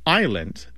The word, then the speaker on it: island: So we don't pronounce the S in island because the French don't pronounce the S in Isle.